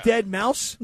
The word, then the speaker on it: Deadmau5: It's spelled DEADMAU5. That's Dead Mouse? – It's just creative.